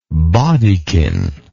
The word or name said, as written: baudekin